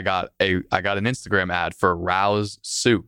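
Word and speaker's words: Rao's: And she told me to get something called Rouse. It's spelled R-A-O. So we said the name Rouse multiple times over the phone.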